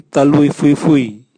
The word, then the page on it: taluvwiuvwiu: Raga